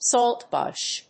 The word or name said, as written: saltbush